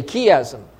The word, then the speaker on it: chiasm: Because here in verses 1 to 4, we have what is known as a chiasm, or some pronounce it chiasm, but because the Greek letter is chi, I'll say chiasm.